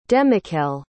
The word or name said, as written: demichael